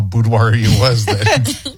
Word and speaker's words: boudoir: Boudoir. Isn't it? That's how you say it, right? – Boudoir, yes.